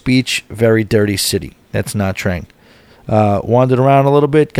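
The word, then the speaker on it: Nha Trang: N-H-A Trang. Na Trang. Naha. Na Trang.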